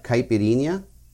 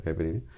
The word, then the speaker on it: caipirinha: It's like a coconut caipirinha. In fact, that's actually exactly what it is a coconut caipirinha.